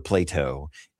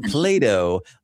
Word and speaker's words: Plato: But I do want to say that we say his name Plato. It's not like the philosopher Plato. It is Plato, like the kid's toy the squishy kid's toy, Play-Doh, that's a really good.